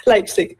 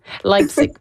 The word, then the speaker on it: Leipzig: Je sais pas si je le prononce sûrement pas bien là, comme une Allemande, là, mais comment tu dis? – Leipzig. – Leipzig, voilà. Quel avantage ou en quoi se distingue l'Allemagne dans le domaine de l'illustration? – C'est une bonne question.